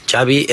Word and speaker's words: Xavi: What is the correct pronunciation of your first and last name? – Xavi Hernandez. – Xavi like chocolate.